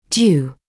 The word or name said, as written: due